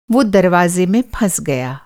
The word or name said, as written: फँसना